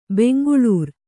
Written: ಬೆಂಗಳೂರು

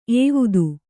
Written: ಏವುದು

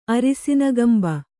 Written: ಅರಿಸಿನ ಕೊಂಬು

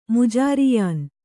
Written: ಮುಜಾರಿಯಾನ್

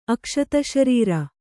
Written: ಅಕ್ಷತಶರೀರ

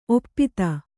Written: ಒಪ್ಪೊತ್ತು

